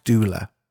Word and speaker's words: doula: The term is a death doula. – Gosh. – Something really interesting to look at. and research, actually. I think it's spelled D-O-U-L-A, as in doula.